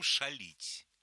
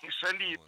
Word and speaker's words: шалить: Абсолютно мы же сейчас говорим шалить. – Шалить, точно. – Шалить, конечно. – А это оттуда. – Причем самое интересное, что шалить – это современное ударение этого слова. В 19 веке говорили шалить.